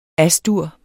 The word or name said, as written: As-dur